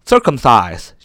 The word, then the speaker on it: circumcise: 这个 circumcise 是由三个部分组成。第一个 c i r c u m，circum 这是一个词根，意思是圆的意思。第二部分是 c i s，就是切的意思。第三部分是 e 啊，放在字尾不发音，没有任何的意义。